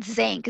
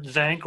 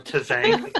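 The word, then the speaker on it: Tzanck: I've heard it Zanc. Is that what you guys think of it as? Silent yeah, T. What's with the silent T, folks? Can you help us out? – I have never met Dr. Zanc or Tazank. Considering. – Come on. – I'd go with Zanc, yeah.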